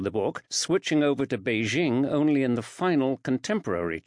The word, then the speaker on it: Beijing: This was entirely logical, in the sense that Beijing is the correct pronunciation of the name in Mandarin Chinese.